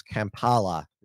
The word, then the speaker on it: Kampala: I'll just make sure that I get the pronunciation of it right. It's pronounced Kampala there.